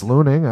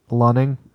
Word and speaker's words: Lunning: I was like, I think it's Looning. I don't know. – And you're like, okay. Well, you were positive. I was like, fuck it. – I wasn't positive. I was just like, I don't know how else would it be pronounced. – I trust you. – How is it pronounced? – Lunning.